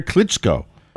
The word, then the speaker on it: Klitschko: Yeah, her boyfriend, Ukrainian heavyweight boxer, a big, big name, Vladimir Klitschko. – Oh, I've heard of him. He's when you say it right.